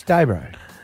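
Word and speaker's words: Dayboro: It's Daybro, yeah. There's no R. It's O, Daybro.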